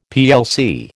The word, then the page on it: plc: American English